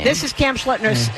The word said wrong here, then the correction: Schlittler: Schlittler's, excuse me.